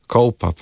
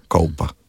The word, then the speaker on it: Kolpa: ker sem rekel Kolpa, bi se takoj popravil, Kolpa pravijo, tam dol, da me ne bodo, ne, ker lokalni izgovor mislim, da moramo spoštovati in lahko jaz izgovorim Kolpa.